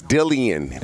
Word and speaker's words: Dillian: No, it's Dillian. That's how he wants it pronounced.